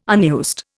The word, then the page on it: unused: Indian